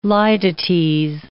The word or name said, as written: litotes